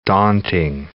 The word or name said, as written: daunting